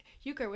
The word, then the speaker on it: euchre: Dad wants to teach us euchre. Is that how you say it? – Yeah, I'd like to learn euchre.